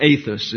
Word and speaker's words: ethos: That's ethos, or ethos. It's a long A in the Greek.